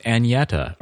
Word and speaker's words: Agneta: But apparently the person that we discussed the pronunciation of, and I tried to like fancify it up, apparently it's pronounced Agneta or Agneta.